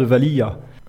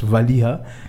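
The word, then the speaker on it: Valiha: Si, si, il y en a qui prononcent Valia, mais c'est Vali, mais souvent on le H à la fin, on, peut l'oublier, c'est le Vali ce Valia.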